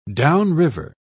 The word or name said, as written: downriver